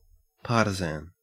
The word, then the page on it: partisan: Australian English